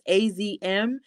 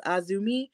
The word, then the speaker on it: AZM: So when it comes to her, it seemed like she, of course, like I said, didn't take too kindly to having Mercedes pronounce her name as AZM, because, of course, it is spelled out AZM, even though it is Azumi.